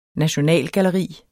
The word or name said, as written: nationalgalleri